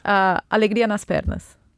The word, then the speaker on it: Alegria nas pernas: Yes, How do you say that in Portuguese? – Alegria nas pernas. Yeah. Repeat with me. – Alegria nas pernas. – Yeah, really good.